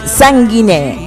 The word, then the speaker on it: Sanguiné: Mi nombre es María José Sanguiné. Acento en la E para algunos.